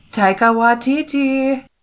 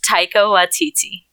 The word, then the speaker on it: Taika Waititi: Taika Waititi. – His name is pronounced Taika Waititi.